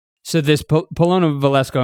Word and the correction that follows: Polina Valesko: am I saying her name correctly? – Polina Valesko. – yes.